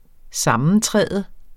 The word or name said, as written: sammentræde